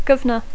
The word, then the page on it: governor: English